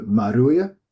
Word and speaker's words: Maruia: Also Maruia. So the R is like a D, so you shouldn't have a problem with that.